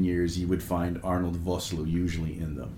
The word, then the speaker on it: Vosloo: Vosloo.